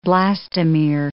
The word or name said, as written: blastomere